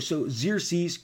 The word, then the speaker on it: Xerxes: So Xerxes, great name, got the X's in there, sounds like Z's.